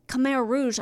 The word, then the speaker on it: Khmer Rouge: He makes, but maybe the terror of living under the Khmer Rouge, I yes. – know I'm saying it wrong. – No, I think you are saying it correctly.